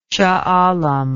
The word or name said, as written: shahalam